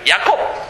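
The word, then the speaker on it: Ya'akov: But usually we pronounce his name Jacob, right? Yaakov. And we don't pronounce iron in between. One consonant isn't really pronounced.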